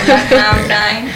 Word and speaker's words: Black Mountain: Black Mon... No sé cómo se pronuncia. – Black Mountain. – ¿Black Mountain? – Sí.